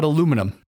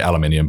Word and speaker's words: aluminium: So aluminum is aluminium, but we do spell it differently as well. So we uh, we put a, an extra U in there, which is for a few different words. So it's one of those that's said differently. We say aluminium, but we also spell it differently as well.